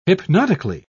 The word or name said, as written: hypnotically